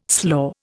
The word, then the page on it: slaw: Indian